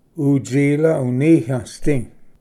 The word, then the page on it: ᎤᏥᎸ ᎤᏂᏴᏍᏗ: utsilv-uniyvsdi